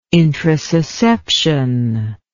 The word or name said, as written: introsusception